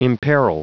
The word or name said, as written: imperil